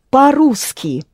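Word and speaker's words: по-русски: Paruski. Mm-hmm. Where you pronounce ru hard, r followed by u, and its counterpart in the lower row is what? – Yeah.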